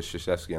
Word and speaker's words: Krzyzewski: especially with Krzyzewski on the sideline. He's a mastermind. He's one of the greatest to ever do it. – You nailed that pronunciation, too.